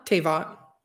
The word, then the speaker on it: Teyvat: Teyvat.